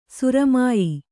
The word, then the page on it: ಸುರಮ್ಯ: suramya